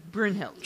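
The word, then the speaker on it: Brynhild: but her name was Brunhild Brunhild Paulstatter Storseth. B R Y A B R Y N H I L D. Brunhild. – Jesus. – Right? And I think of Brunhilde, right?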